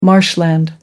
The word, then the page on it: marshland: American English